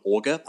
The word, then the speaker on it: Augur: It's called Augur, I think. That's how you pronounce it. It's A-U-G-U-R.